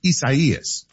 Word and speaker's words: Isaías: So this is a Spanish form of Isaiah. So it's pronounced Isaías.